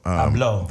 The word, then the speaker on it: Abloh: Abloh. – Abloh.